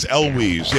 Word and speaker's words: Elwes: Kerry Elwes, is that how you say it? – I think it is Elwes.